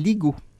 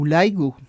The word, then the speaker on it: LIGO: Le premier qui a fonctionné s'appelle LIGO, ou LIGO, si on prononce en anglais.